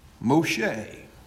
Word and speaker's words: Moshe: Moshe, if you were to pronounce his name in Hebrew, or Moses as we would call him.